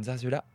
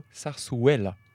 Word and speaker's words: zarzuela: Je tiens à dire d'abord que nous avons toutes mal prononcé parce que les deux Z se prononcent et le U se prononce ouais. Donc on dirait plutôt zarzuela.